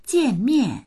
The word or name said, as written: jian4mian4